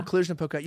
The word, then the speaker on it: Cluj-Napoca: I can't even pronounce the name of this city. So guys, Cluj-Napoca. – Cluj-Napoca. – No, Cluj-Napoca.